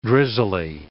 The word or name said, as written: drizzly